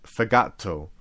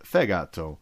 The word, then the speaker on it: fegato: And very random fact, but there's no Italian word that rhymes with fegato. Although there are a lot of ones that end in egato. Sorry. And that's because the stress is fegato, fegato, fegato, and not fegato, like I said, but fegato.